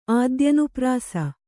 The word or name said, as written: ಆದ್ಯನುಪ್ರಾಸ